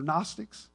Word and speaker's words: Gnostics: They were called, remember, it begins with a G. The G is silent. They're Gnostics. And they taught the Christ, this is what they taught.